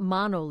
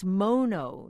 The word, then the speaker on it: Mono: Joanne Michella of the Watsonville Health Center writes, "It's not Mono Lake, it's Mono, named for the Mono Indians, the hardy native folk who lived off the brine shrimp in the lake for generations.